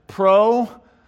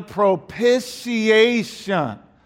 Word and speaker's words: propitiation: This is how you say this word. Propitiation. You can say it with me. Propitiation. You put it all together. Propitiation.